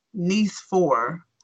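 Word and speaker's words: Nicéphore: It's not pronounced nice, as in very nice. The French way of saying it sounds like niece four.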